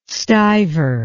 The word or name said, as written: stiver